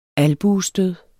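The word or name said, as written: albuestød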